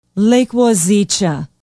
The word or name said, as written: Lake Wazeecha